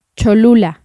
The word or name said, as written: Cholula